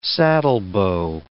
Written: saddlebow

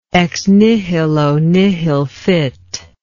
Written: ex nihilo nihil fit

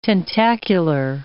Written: tentacular

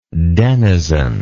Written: denizenship

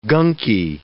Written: gunky

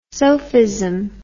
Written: sofism

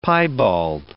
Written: piebald

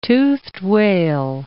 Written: toothed whale

